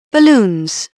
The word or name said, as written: balloons